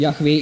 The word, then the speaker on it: Jahwe: Kann man Vokale einsetzen, Jehova oder Jahwe. Und Jahwe ist die ursprüngliche Aussprache, sehr wohl.